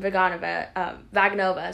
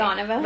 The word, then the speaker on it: Vaganova: and we train in Russian Vaganova. Sorry, I never get the pronunciation right. – It's Vaganova. – Right. Vaganova?